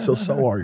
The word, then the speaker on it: SoaR: I went up to him, I was like, is it pronounced Soar? And he goes, Soar, because it has a capital A in the middle of it.